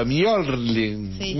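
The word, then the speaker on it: Mjölnir: Para mí la J no va, eh, porque es la M y la J se transforma en una I. – ¿No, es el Mjorling? Ahí está, gracias, yo me parecía. se pronuncia raro, Mjorling.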